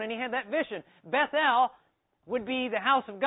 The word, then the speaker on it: Bethel: So they went down to Bethel." I pronounce that the English way. It should be Bethel, because El refers to Jehovah.